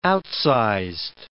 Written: outsized

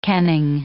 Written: kenning

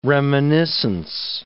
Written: reminiscence

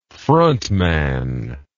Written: frontman

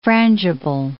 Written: frangible

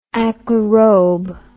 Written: aquaerob

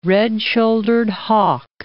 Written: red-shouldered hawk

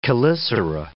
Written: chelicera

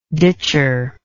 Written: ditcher

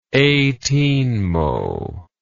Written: eighteenmo

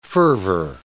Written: fervor

